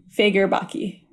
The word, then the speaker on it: Fagerbakke: Um, oh, here the pronunciation on Wikipedia is uh, Fagerbakke.